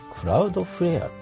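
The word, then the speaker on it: Cloudflare: ちょっとクラウドフレアって読むんだな読み方は、クラウドフレアとアップストアで検索かけるとこのアプリ、出てきますね。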